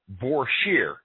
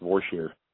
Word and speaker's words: Vorsheer: And I'm proud to say it's all American made Vorsheer. Am I saying that right? – Yep, you're saying that correct. It's Vorsheer.